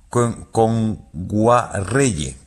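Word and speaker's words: Kngwarreye: Nguayere, lo he dicho mal. – Emily Kame. – Con Guarreye.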